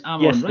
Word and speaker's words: Amon-Ra: It's Amon-Ra.